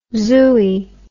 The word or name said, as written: zooey